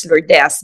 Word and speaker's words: Lourdes: I am from the Philippines, which is Lourdes, but it's actually the same as the French pronunciation for Lourdes, and it gives us a background of where people come from.